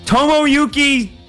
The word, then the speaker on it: Tomoyuki: Tomasaki? No. Tomoyuki. Tomoyuki Sugano. Gotta be it. – Nailed it. Nailed it.